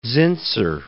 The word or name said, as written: Zinsser